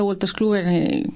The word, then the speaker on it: Wolters Kluwer: como los clientes de Wolters Kluwer, Wolters Kluwer hace, como has dicho muy bien antes, y lo has pronunciado muy bien, que es raro porque tenemos un nombre muy complicado,